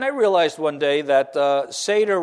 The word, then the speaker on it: Seder: So when you leave here this morning, you can kind of repeat to yourself, Nader, Vader, Passover Seder, like that. Maybe where no one else can hear you, but you'll have the pronunciation down perfectly, a great way to learn the word Seder.